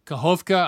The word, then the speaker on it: Kakhovka: So you grew up in Kakhovka. I don't know if I pronounced that properly. That was perfect.